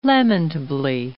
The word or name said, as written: lamentably